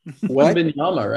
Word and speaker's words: Wembanyama: Wembanyama, right? – Wembanyama, whatever.